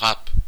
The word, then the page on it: râpé: [ʁɑːp]; /ʁɑp/